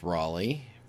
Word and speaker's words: Rollie: Rollie Fingers, pronounced Raleigh, was one of the more recognizable of Catfish Hunter's teammates.